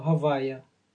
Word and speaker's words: Havaya: E noi pronunciamo questo Havaya. Usiamo questi nomi in questo modo, questo nome, per non usare il suo nome invano. Pronunciamo Havaya. Quindi diciamo Havaya.